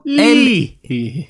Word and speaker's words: Leahy: Leahy. Ursäkta uttalet, jag har ingen aning. – Leahy. – L-E-A-H-Y. Leahy. Ja, det var ju spännande. – L-E-A.